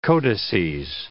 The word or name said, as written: codices